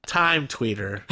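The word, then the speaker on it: Tyme Tweetr: Time Tweeter. But it's spelled with a Y. Time. T-Y-M-E. Tweeter is... It's T-W-E-E-T-R. – Tweeter. – So they take out little vowels.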